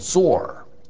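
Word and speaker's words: Zor (צור): Now, on the other hand, Tyre in Hebrew is called Zor, T-Z-O-R to write it out phonetically.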